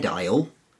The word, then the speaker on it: aisle: So if you go to the bread aisle in the local supermarket, the A's in bread, B-R-E-A-D, and aisle, A-I-S-L-E, contribute nothing phonetically.